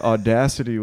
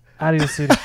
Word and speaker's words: Audacity: So, thought audacity was pronounced. What? – Out of your city.